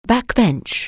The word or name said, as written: backbench